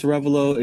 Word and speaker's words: Arevalo: So my name is Francis Arevalo. It's spelled Francis My last name is spelled A-R-E, V as in Victor, A-L-O.